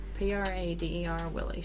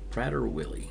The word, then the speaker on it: Prader-Willi: It's spelled P-R-A-D-E-R-Willi syndrome. – Prader-Willi.